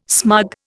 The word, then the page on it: smug: Indian